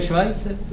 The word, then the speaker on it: Schweitzer: Myslíte náhodou Albert Schweitzer? – No já myslím toho, jak v Africe tu nemocnici jak nechal postavit. – No to se vyslovuje Švajce.